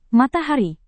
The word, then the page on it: matahari: Indonesian